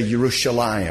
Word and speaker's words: Yerushalayim: Like they say Yerushalayim, and we say Jerusalem. They say Yerushalayim. They don't pronounce the J like we do. Most languages don't have a J in it.